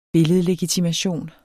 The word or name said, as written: billedlegitimation